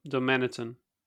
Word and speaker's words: Darmanitan: En de reden daarvoor, dat las ik pas later, is dat Darmentian, zeg ik het goed? Darm Darmanitan. Darmanitan is Pokémon nummer 555.